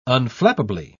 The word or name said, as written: unflappably